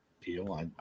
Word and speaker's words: Piel: It's a, I'm going to butcher this name, P-I-E-L. Pile? Peel? – Peel.